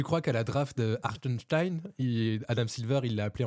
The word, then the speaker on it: Hartenstein: Hartenstein, j'aime... si tu veux bien le prononcer, il faut penser que tu es un peu un nazi qui appelle des juifs. Quand il fait une liste dans un stade.